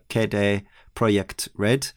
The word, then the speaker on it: CD Projekt Red: Obviously, we know the developer is CD Projekt Red, although we've also learned recently from Danny O'Dwyer's video that actually we've been saying that wrong as well, because it should be something more along the lines of Kedere Project Red, but we'll stick with the anglicised version because it sounds more natural coming from us English speakers, I think.